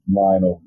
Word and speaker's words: Lionel: And you just simply just search with my first and last name, Lionel Garrett. My first name is spelled L-I-O-N-E-L, and my last name is G-A-R-R-E-T-T.